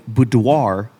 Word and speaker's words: boudoir: A boudoir. Am I saying it wrong? – No, I think you're saying it right.